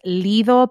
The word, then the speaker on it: Lido: And I have one more for you because she's a whole ass vibe and her name is Lido Pimienta. It's spelled L-I-D-O.